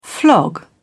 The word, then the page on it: flog: British English